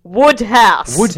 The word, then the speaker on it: Wodehouse: The A is silent. It's pronounced Woodhouse.